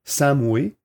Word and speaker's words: Samhain: Donc, si on, va dans le dictionnaire, euh, dans l'encyclopédie Britannica, l'origine, c'est la fête Sam, ça se prononce Samway, mais ça s'écrit Samhain mais la, prononciation est, différente, OK.